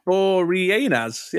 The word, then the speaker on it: Boreanaz: Yeah, it is Boreanaz.